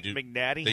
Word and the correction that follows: McNanty: How do you say his last name again? – McNanty. – McNanty, that's it.